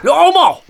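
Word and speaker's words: Louhimo: That was Battle Beast and the swollen red bulging pipes of Nora Luhimo. – Luhomo! Basically, if you get her name wrong.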